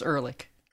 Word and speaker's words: Ehrlich: It's Ehrlich.